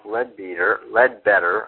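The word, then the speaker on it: Leadbeater: It's available, and if you look there, there's this great quote I want to mention by Charles Ledbetter, actually his name is pronounced.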